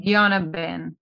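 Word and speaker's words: Pidyon Haben: And you still have a thing called the Pidyata Ben. Is that how you say it? Pidyata Ben? – Yes, correct. – Pidyata Ben. And so we had a ceremony. – Pidyana Ben.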